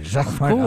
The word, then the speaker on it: Jacquemart-André: Jacquemart-André. Jacques or no, you say it like André. A Frenchman. – Jacques-Marc André. Of course, because I'm French. So Jacques-Marc André.